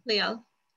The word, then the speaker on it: Leal: You're going to tell me if I'm saying your name incorrectly, I hope. Ana Leal? – That's fine. – No, really. How do I say it? – That's fine. Yeah, Leal.